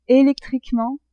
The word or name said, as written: electriquement